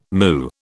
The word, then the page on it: moo: British English